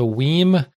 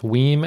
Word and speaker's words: WiiM: He's referencing the story that we talked about last episode about the Weim amp. I'm still going with Weim as the pronunciation of W-I-I-M, capital M for some reason.